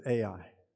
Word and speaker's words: Ai: in Texas, we pronounce it Ai. That is so far from the Hebrew, it's unbelievable. It's more like A-E, but anyway, try that.